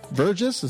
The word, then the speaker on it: Vergis: Vergis, is that how you pronounce the name? – Soft G, yes, exactly.